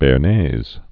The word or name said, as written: bearnaise sauce